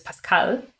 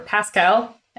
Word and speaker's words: Pascal: So the closest language to it is Pascal, or Pascal, as I heard the American pronunciation for it.